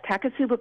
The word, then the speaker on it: Takotsubo: One that I've heard of, which I hope I'm pronouncing correctly, is Takotsubo cardiomyopathy. – Yes, Takotsubo cardiomyopathy. You're absolutely right.